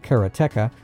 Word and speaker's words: Karateka: I mean, I enjoyed some Karateka back in the day. – And some of you may pronounce that Karateka.